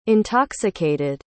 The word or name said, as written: intoxicated